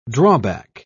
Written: drawback

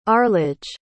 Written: arledge

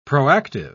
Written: proactive

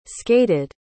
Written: skated

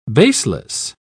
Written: baseless

